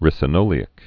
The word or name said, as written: ricinoleic acid